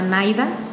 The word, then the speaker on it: NIDA: Hay otra página que se llama NAIDA, se escribe así, N-I-D-A.